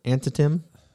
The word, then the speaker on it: Antietam: In September 1862, the following, following the Union victory at the Battle of Anaheim, or er, no, that's not right. Ant, ant, I can't even pronounce it. Antitim, A N T I E T A M